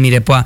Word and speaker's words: mirepoix: Se escribe mirepoix con X, uh-huh. Pero es el mirepoix.